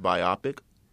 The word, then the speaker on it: biopic: You know it's actually pronounced biopic? – Biopic? – Yes, like biology.